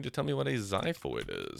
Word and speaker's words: xiphoid: Our X word is xiphoid, is how I would pronounce it. It is X-I-P-H-O-I-D. I'm not going to lie. I never really looked into if there are other rules for X's. I learned that it sounds like a Z in xylophone, and I was like, that's good enough forever.